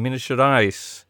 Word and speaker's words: Minas Gerais: Uh, they took on, on June 29th, an amateur USA side in uh, Belo Horizonte in Minas Gerais.